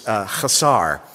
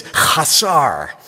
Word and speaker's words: chasar: The word here, the Hebrew word is chasar. And you really do need to say it like you've got something caught in your throat. It's chasar. And it means I shall not lack.